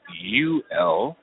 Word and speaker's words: Yule: Given the unusual spelling of his name, pronounced U-L, it would seem that a key tactic would be to educate voters how to write it in on the ballot.